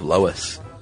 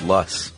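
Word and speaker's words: loess: Furthermore, just finished your wonderful episode on ice ages and giggled a little from your pronunciation of Lois. I was always told it was pronounced Luss.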